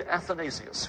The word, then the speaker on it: Athanasius: You've probably heard his name, and I expect you can spell it, but just in case, let me spell it for you, A-T-H-A-N-A-S-I-U-S, Athanasius.